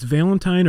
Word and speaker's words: Valentin: So today we have a special episode for you about Valentin Blatz. Is that right? – Yeah, that's the Swedish pronunciation. It's Valentine or Valentin. – Okay. – Swedish German is probably Valentin.